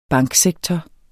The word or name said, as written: banksektor